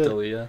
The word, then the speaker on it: D'Elia: How do you pronounce it? – D'Elia, I think. – D'Elia, yeah.